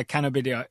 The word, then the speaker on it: cannabidiol: Yeah, I went with uh, cannabidiol.